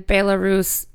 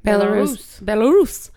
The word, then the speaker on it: Belarus: Yeah, so many orphanages or healthcare facilities, specifically in Belarus. Am I saying that right? – Yeah. – Okay, Belarus, where children to this day in 2018 are living with what is suspected radiation cause birth defects.